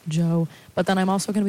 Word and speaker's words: Djo: And Gap Tooth Smile by Joe is how you pronounce it. It's spelled D-J-O, and a lot of people pronounce it like that, but that's technically wrong, I think.